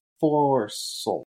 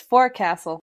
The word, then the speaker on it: forecastle: I would have led you astray on forecastle, and then one article I happened to find said, it's pronounced forecastle.